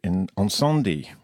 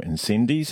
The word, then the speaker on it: Incendies: The first is called Ensendi, or Incendies, as we like to pronounce it down here,